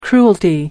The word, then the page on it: cruelty: American English